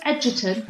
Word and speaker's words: Egerton: Is that how we say his name? Are we going with Edgerton? – Yeah, that's it.